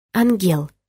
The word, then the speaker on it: Ангел: Листая страницы, я вдруг увидела «Ангел» с ударением на «Е».